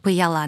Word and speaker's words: Паяла: Он называется «Паяла». – «Паяла». – «Паяла», да.